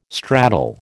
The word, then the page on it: stratal: American English